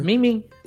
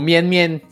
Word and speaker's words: Min-Min: Eu falei certo o nome dela? Eu falei, né? – Min-Min. – Uh-huh. Min-Min. – Min-Min. Ou Mien-Mien, se você for falar em japonês.